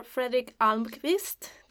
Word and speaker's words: Fredrik Almqvist: We have today with us Professor Fredrik Almqvist.